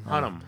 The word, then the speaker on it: Hunnam: How do you say his last name? – It's a pretty fun one. Charlie Hunnam. – Hunnam.